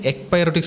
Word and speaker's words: ekpyrotic: So the cyclic ekpyrotic scenario was put forward to explain as one of the better explanations of the string scenario.